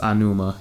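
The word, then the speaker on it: Aonuma: Eiji, I think his name is pronounced Anuma,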